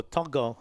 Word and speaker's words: Tago: The centers for Panthers, we got Isaac Tago, or Togo, I think is how it's pronounced, and Stephen Crichton.